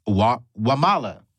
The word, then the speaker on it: Wamala: It's Jacint Wamala.